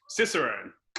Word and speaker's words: Cicerone: How you pronounce it? – Cicerone.